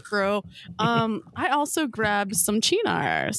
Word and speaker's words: Cynar: It's spelled like C-Y-N-A-R. – Right. Yeah. – But it's okay. Pronounced Chinar.